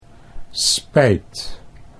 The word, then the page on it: spijt: Dutch